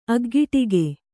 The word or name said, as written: ಅಗ್ಗಿಟ್ಟಿಕೆ